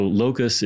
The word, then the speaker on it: locus: So locus is not like the bug. But it's spelled the same. – You hear it buzz. – You don't have a T at the end.